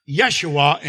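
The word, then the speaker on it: Yeshua (ישוע): Jesus is pronounced Yeshua in Hebrew, and it's the same name as Moses' assistant Joshua, a deliverer.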